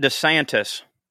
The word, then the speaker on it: DeSantis: I guess for a long time, he went by DeSantis. But then he changed the pronunciation. – Pecan. – To DeSantis. – Right. – But he's gone back and forth on it.